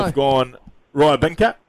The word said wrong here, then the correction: Rybakina: Not quite. – Rybakina, close. – Rybakina, Elena, for short.